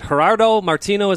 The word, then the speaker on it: Gerardo Martino: Jeff, shifting gears a bit, you had just reported yesterday that Atlanta United is in talks with former Argentinian national team manager, is it Gerardo Martino? Is that how you say it? – Yes.